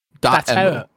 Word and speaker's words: Dotemu: Is it Dotemu? – Dotemu.